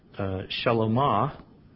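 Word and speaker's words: Shlomo: And David the king begot Solomon, which is pronounced Shalomah, which means the peaceful one, by her who had been the wife of Uriah.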